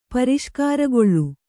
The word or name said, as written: ಪರಿಷ್ಕಾರಗೊಳ್ಳು